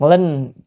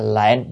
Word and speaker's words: linh: Chữ linh, chữ này á, còn âm khác là lệnh, nhưng ở đây phải đọc là linh. Linh thì khiến cho, còn nếu lệnh là mệnh lệnh, ra lệnh.